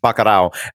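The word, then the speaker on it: Bacurau: Best Foreign Language Film is the film Baccarat. Am I saying that properly? – Baccarat.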